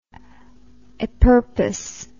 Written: a purpose

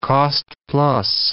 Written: cost-plus

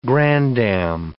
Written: granddam

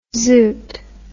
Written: zoot

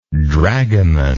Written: dragomanish

